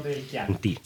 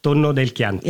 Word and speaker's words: Tonno del Chianti: Chef, the Il Tonno di Chianti uses pork rather than your traditional tuna. Where does that name come from? I hope I said it right. How is it prepared? – Tonno del Chianti.